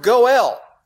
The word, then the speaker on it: goel: And it's the word goel. In English, we would spell it G-O-E-L. Goel. Two syllables. G-O-E-L. Goel, the kinsman redeemer.